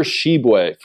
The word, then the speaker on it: Tshiebwe: Oscar Tshiebwe from Kentucky. – You nailed the pronunciation, though.